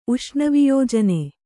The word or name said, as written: ಉಷ್ಣವಿಯೋಜನೆ